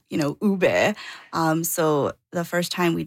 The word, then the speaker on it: ube: Ube.